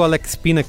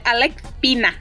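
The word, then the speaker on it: Álex Pina: Queria só falar um pouco do Alex Pina, que é o showrunner, criador da série. – Você tá falando errado, é Alex Pina.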